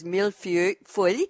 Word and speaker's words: millefeuille: The way I pronounce it is millefeuille, M-I-L-L-E-F-E-U-I-L-L-E.